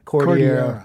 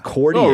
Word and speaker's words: Cordillera: How do I say it? – Cordillera. – Cordillera. – Oh, really? I didn't know that.